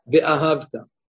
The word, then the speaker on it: Ve'ahavta: First of all, don't pronounce it in the mistaken way like many people do. We've spoken about this many times. Ve'ahavta, right? They teach especially to Ve'ahavta et Hashem.